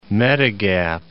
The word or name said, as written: medigap